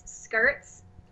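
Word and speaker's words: Skirtzzz: It's Skirts. So it's spelled like skirt, and then it has ZZZ. It's three Zs.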